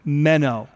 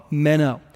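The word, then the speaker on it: meno (μένω): The word in Greek is the word meno. It'd be like M-E-N and a long O, meno.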